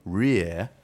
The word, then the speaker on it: Reay: Sixth form student Emily Rear is barred, because her name is spelled R-E-A-Y, Rear,